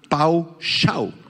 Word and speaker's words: pawshah: Paushah, la voy a deletrear, P-A-W-S-H-A-H, con acento en la segunda A, paushah.